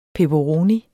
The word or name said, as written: peperoni